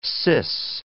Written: cis

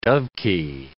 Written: dovekie

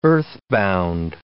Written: earthbound

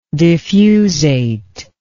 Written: diffusate

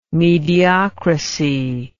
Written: mediocracy

